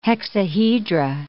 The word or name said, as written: hexahedra